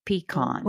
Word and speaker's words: pecan: How do you pronounce that word? – Yeah, it's pecan.